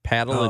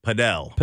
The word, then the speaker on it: Padel: For Roaming St. Louis, Paddle and Pickle Club. – Padel.